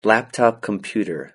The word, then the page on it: laptop computer: British English